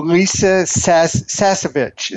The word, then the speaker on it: Lisa Sasevich: Aaron, you contributed a book to Lisa Sasevich. Is that how you pronounce her name? – Yep.